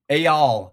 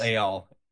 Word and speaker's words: Eyal: Eyal. Eyal. Yes. Eyal.